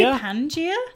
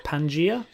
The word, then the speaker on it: Pangaea: How do you pronounce it? – I don't know. – Pangaea? – I would say Pangaea. – Pangaea.